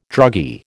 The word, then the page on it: druggy: American English